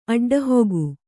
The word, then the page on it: ಅಡ್ಡಹೊಗು: Kannada